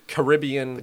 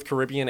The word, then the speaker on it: Caribbean: We're going to the Caribbean. They pronounce it both Caribbean and Caribbean.